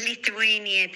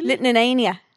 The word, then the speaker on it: Lithuania: Litnanania.